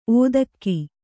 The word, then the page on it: ಒದಗೆ: odge